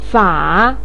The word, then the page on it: fa: 3